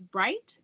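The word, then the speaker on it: Bryght: So my name, I'll just state it again, is Lauren Bright, and it's L-A-U-R-Y, like yellow, N. And my last name is Bright, B-R-Y, like yellow, G-H-T.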